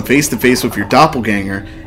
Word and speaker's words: Doppelgänger: You know, it's actually pronounced doppelganger. – You know you're full of shit. – The bell. It will gong 27 times. The gonger. – No, but in all honesty, he used to depend on gonger. – The German pronunciation is doppelganger.